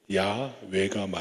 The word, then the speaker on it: 야훼: 원래 그 히브리식 발음으로 말하면 하나님의 이름은 뭐예요? 야훼가 맞아요.